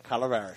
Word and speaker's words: Calaveras: Calaveras.